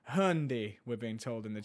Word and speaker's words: Hyundai: And that is Hyundai. Or hang on, how do you say it in American? We might have American listeners. – Hyundai.